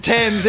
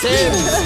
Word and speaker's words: Thames: The second one's silent. – Yes, the Thames. – It's the Thames. – It's hard to pronounce if you are looking at it. Yeah. That's exactly right. – What a nightmare. – It's almost like, yeah. – You look it up and you go, the Thames? – What is the point in the H? – Yes, they pronounce it the Thames.